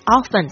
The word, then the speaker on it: often: 那就是这个如果说它是搭配 how often 的话，就是来问这个频率 how often 多久一次的意思。那但是这边发音有一个状况，就是 often 常常会讲成 often often O-F-T-E-N，把那个 T 发出来了，这个也是很常听到的发音，就是 often 或 often 都可以。